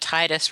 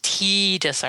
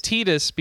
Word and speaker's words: Tidus: We can have some good Titus versus Tidus arguments. – Well, it is Tidus because they've come up and said his name officially.